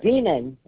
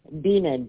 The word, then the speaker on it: Beaman: Beaman. Beaman. B like boy, E-A-M-A-N, like B-A-M-A-N.